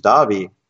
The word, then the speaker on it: Davi: Yeah, I mean, I've had Indian people say it has to be Darvi, it can't be pronounced Davi.